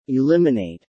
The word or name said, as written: eliminate